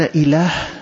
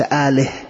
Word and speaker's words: إله: قل أنتم أخطأتم حتى في اللغة، فإن إله بمعنى معبود نحن ننطقها إله وليس آله.